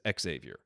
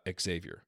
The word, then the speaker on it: Xavier: He said, the best basketball announcer in the world pronounced it like the university in Cincinnati, so Xavier, until he was corrected by the family. It is Xavier.